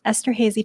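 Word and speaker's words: Esterházy: One of them that really caught my eye was um, correct me if my pronunciation is wrong. Is it the he- Esther Hazy torte? – Oh, yes. Nope, that's correct.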